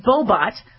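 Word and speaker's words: Bohbot: Veronica Bobot from McGill University in Canada led a team of, yeah, it's Bobot or Bobot, B-O-H-B-O-T. I love that name because it rhymes with robot.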